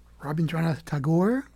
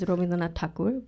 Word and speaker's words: Rabindranath Tagore: Yes, that was correct. The Bengali way to say it is Rabindranath Tagore, but the way you said it is also correct.